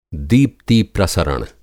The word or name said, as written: दीप्ति-प्रसरण